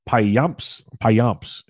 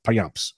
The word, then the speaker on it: Payamps: Joel Payamps is how I pronounce it, and that's how I looked it up, and that's the closest thing I can find is Payamps.